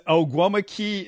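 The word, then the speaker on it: Ogwumike: Yeah, it's Oguimike is how you pronounce it.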